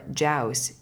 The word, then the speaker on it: Jaus: The name of the spirit of the decan, according to one source, is Cho, and it's spelled C-H-O-O-U, or Jaus, G-A-U-S. Sorry, J-A-U-S.